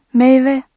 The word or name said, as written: meyve